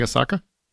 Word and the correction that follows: Ahsoka: How do you say it? – Ahsoka. – Yeah. Ahsoka. I definitely didn't say it like that. I said it more like Japanese whiskey.